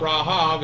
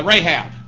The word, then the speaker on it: Rahab: Interestingly enough, Rahav is the name of the hooker in Joshua there that saw the spies out and protected them. And Rahab is how we would pronounce it, but Rahab the harlot, her name comes from this root as well.